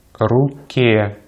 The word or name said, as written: руке